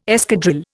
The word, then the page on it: escadrille: Indian